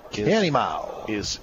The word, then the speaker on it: Canimao: Canimao is how it's pronounced.